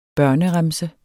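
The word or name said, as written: BØRNEREMSE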